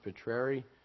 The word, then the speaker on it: Petreri: Our last name is pronounced Petreri. It's Italian. I don't look it, but it is.